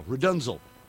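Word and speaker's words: RDNZL: And then after that, it goes into Rudunzel, which is spelled R-D-N-Z-L. R-D-N-Z-L, Rudunzel.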